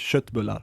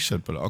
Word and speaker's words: köttbullar: Ich weiß nicht, ich probiere das Deutsch zu sagen oder etwas, aber das heißt eigentlich Schöttbullar. – Eigentlich Schöttbullar, okay.